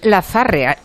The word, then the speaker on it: Lazarre: ¿Cómo debían de pronunciar los americanos? Jane Lazarre. Jane vale, pero Lazarre, ¿cómo lo debían pronunciar? – Lazarre. – Lazar. – Vale, Bien, Jane Lazar.